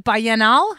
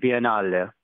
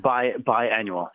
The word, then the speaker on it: Biennale: Is it the Biennale? How do I pronounce that? – If we're cultured, we call it Biennale. – Biennale, okay. If we're not cultured, then we call it biannual.